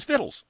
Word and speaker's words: victuals: Yeah, and unless you know that spelling is this word, you would never imagine that it's pronounced as vittles.